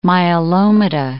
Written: myelomata

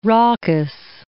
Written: raucous

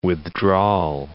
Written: withdrawal

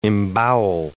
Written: embowel

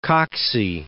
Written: Coxey

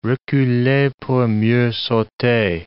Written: reculer pour mieux sauter

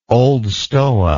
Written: Old Stoa

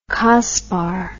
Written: cospar

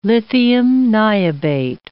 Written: lithium niobate

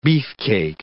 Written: beefcake

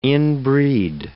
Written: inbreed

